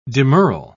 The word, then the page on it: demurral: British English